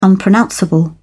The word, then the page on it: unpronounceable: British English